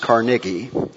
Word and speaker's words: Carnegie: and it was built by the philanthropist Andrew Carnegie, or it can be pronounced Carnegie, in 1891.